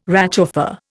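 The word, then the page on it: réchauffé: Indian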